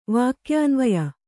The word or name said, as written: ವಾಕ್ಯಾನ್ವಯ